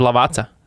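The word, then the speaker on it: Lavazza: Nu, Itāļiem, piemēram, divi Z ir C. Nu, piemēram Lavaza. – Jā, jā. – Ir Lavaca. Jā.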